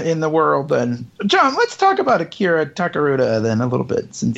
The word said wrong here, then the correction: Takarada: Akira Takarada. – Takarada.